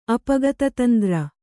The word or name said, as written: ಅಪಗತತಂದ್ರ